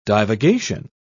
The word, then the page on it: divagation: British English